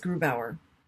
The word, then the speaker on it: Grubauer: But just to point out, it's just Grubauer. You were Grubauer. Grubauer. Sorry, he's German. Gotta tell you. – Sorry, I just like reflexively, you know, put the emphasis on the second to last syllable, which is what you do in most languages.